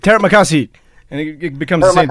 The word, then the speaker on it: Terima kasih: So this is how you say thank you. Terima kasih. – Terima kasih.